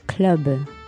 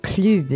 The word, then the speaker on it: club: Moi, je veux dire club. – Et c'est là que les Français le prononcent pas comme ça, malheureusement. – Ok, comment? – Club. – Club. Alors, c'est comme en anglais.